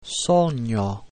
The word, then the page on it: sogno: Italian